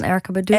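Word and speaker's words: Erykah Badu: En jij hebt een nummer meegenomen, In Love With You, van de zangeres Erica Badu. Zeg ik dat goed? – Ja, van Erica Badu.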